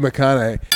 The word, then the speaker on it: McConaughey: How do you pronounce it? McConaughey? – Matthew McConaughey.